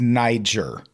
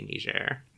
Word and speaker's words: Niger: Yeah, it covers the ambush killing of four American Special Forces troops by ISIS militants in Niger, which I always pronounced Niger. – Yeah, I think the classic American pronunciation is Niger, but I think it's supposed to be Niger.